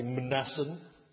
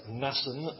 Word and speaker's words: Mnason: You could try and pronounce the M and say Mnason. But it's easy just to make the M silent and say Nason of Cyprus, an early disciple with whom they would lodge.